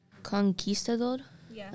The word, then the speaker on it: conquistador: Conquistador. – Conquistador? – Yeah, like that? – Conquistador, yeah.